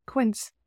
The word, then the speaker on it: Quince: Quince, it rhymes with Prince, which I love because I am a huge Prince fan.